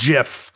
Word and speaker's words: GIF: Pronounced GIF, you noobs. Soft G, baby.